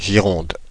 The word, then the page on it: Gironde: [ʒi.ʁɔ̃d]; Paris; French